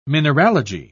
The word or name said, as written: mineralogy